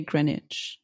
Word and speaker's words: Greenwich: in the Greenwich Village. – Oh, you're pronouncing that wrong. – Oh, shit. How you say it? – On TV, they say Greenwich something like that.